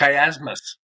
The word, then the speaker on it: chiasmus: And I think it's a chiasma. Is that right? Is that how you say it? – Chiasmus. – Chiasmus.